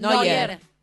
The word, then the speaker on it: Neuer: ¿Quién Neuer. – Podría ser? – Neuer. – ¿Quién podría ser? – Ponemos la foto de Neuer porque no lo tengo. – Es rubio, alto y super alemán. – Neuer.